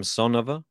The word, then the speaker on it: Samsonova: Samsonova.